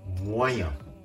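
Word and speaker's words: moyen: ん」を発音しないで飲み込む形で「モアイヤモアイヤと発音するとうまくいくかもしれませんそれでは最後にもう一度発音します。中程度のはフランス語で、モアイアンです。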